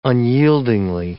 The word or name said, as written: unyieldingly